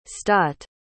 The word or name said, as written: stott